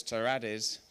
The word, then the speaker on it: Terrades: So my name is actually Spanish. My dad was born in Spain, came over to England when he was a teenager. And if you're Spanish, you're meant to roll your R's. So it's Terrades, but I can't roll my R's. So I can't actually say my own name.